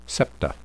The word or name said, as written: septa